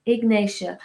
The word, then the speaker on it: Ignatia: Ignatia. I-G-N-A-T-I-A.